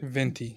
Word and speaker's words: Venti: Venti.